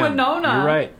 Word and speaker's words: Winona: I actually said her name right. Are you proud? – I said Winona. – You're right, you did.